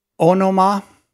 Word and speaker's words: onoma: I find it interesting that the word for name in Greek is onoma, although my concordance said it's pronounced anoma, but it's O-N-O-M-A, onoma.